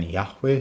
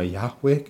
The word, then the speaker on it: Yahweh: Das läuft auf ein Yahweh hinaus. Also, dass dieser Name Yahweh, Yahweh ausgesprochen wird.